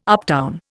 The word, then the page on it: uptown: Indian